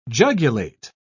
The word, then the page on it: jugulate: British English